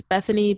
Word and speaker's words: Bethany: It's Bethany, B E T H A N Y dot Phenice, P H E N I C E.